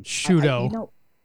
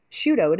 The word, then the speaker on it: Sciutto: This is CNN's Jim how do you say his name? – Shudo. – Shudo, it is Shudo.